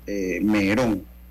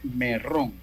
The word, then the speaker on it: Merrón: La gente le dice Merón, pero legalmente se escribe Merrón, M-E-R-R-O-N. Pero yo una vez en una entrevista lo puse como Rodrigo Merrón que subí hace algunos años en las redes sociales. Eh, y entonces me comenzaron a corregir, ¿no? que no era Merrón, sino que era Merón.